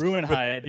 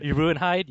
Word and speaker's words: Ruinhide: And then like there are two other characters, Liliana, and oh man, how do you pronounce this? – Ruinhide. – Ruinhide. Yeah. So.